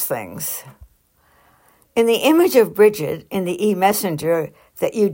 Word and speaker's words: Brigid: Her name, which means exalted and fiery, is actually pronounced with a hard G, so it should be Brigid, though I can't really say that.